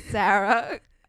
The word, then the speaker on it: Sara: It's Sarah, no H, obvious, SARA_LACAYO.